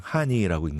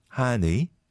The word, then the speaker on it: 하늬: 근데 이제 읽을 때는 그냥 하니라고 읽는데, way you write it is 하니, right? 하니.